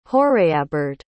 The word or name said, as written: hoireabard